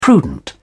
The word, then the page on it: prudent: British English